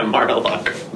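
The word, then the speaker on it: Mar-a-Lago: Yeah, no, he's just at, what is it, Mar-a-Largo? How do you say that? – Yeah, Mar-a-Largo.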